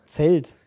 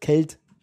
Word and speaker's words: CELT: Jetzt Self heißt es, oder? – Zelt. C-E-L-T. Zelt. Kelt. – Kelt, genau, wie Kelt. – Ja, also ich würde Zelt aussprechen.